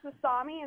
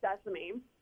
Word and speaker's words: sesame: And then she also said sasami instead of sesame.